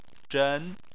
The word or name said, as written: zhen2